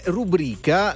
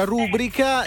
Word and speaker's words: rubrica: Abbiamo la Treccani e rubrica. La pronuncia rubrica è dovuta a un'errata ritrazione dell'accento ed è scorretta.